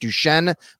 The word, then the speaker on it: Duchenne: Did I say that right, Tommy? Is it Duchenne muscular dystrophy? Is that how you do it? – Yep.